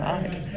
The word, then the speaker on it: Ide: And this is Enjoy the Ride podcast with Brandon Ide. I did Enjoy the Ride because it rhymes with my name.